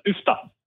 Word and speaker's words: Uffda: We pronounce it as Uffda.